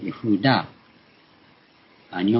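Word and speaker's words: ヤ フ ーダ ー (יהודה): ヤフーダーという形からありますが、ヤハウェをエという省略形。ヤフーダー。それから、これはユダなんですね。ユダっていう名前は、そう、ヤフーダー。日本語では、英語ではジューダーという発音になりますけども、このヘブライ語ではヤフーダーなんです。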